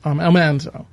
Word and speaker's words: Almanzo: So, so I noticed you used the correct pronunciation, Almanzo.